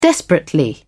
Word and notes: desperately: British English

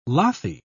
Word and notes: lathy: British English

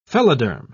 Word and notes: phelloderm: British English

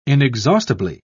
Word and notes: inexhaustibly: British English

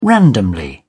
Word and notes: randomly: British English

